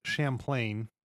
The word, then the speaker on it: Champlain: Well, before we go on to beluga whales, Lake Champlain, if that's how you say it.